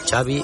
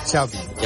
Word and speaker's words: Xavi: Xavi Hernández. – Xavi. – Yeah. Xavi like chocolate.